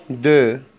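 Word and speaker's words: deux: لي نومبر رقم صفر، زيرو زيرو زيرو رقم واحد، ان رقم اثنين، دو الحرف الأخير لا ينطق، دو دو رقم ثلاثة، تغوى تغوى تغوى، زيغو ان دو تغوى.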